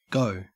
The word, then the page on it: go: Australian English